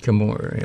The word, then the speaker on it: Chemours: Yeah, but Chemours, is that how you pronounce it? – Chemours, yeah.